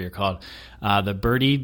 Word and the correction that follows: Bertie: I, I think it's Bertie.